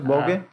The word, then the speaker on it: Vogue: Vogue.